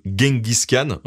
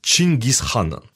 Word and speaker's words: Genghis Khan: Alors là, je vous dis Genghis Khan, hein, parce que dans la francophonie en général, c'est la prononciation la plus répandue. Mais il y a plein d'orthographes et de prononciations différentes, comme Genghis Khan ou encore Genghis Khan. Et la vraie prononciation, c'est même Chinggis Khan.